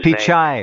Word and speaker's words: Pichai: I keep forgetting how to pronounce his Pichai.